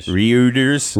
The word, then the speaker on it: Reuters: Reuters.